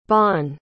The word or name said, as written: Bonn